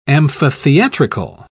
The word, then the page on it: amphitheatrical: British English